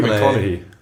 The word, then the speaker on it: McConaughey: Matthew McConaughey.